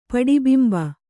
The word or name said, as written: ಪಡಿಬಿಂಬ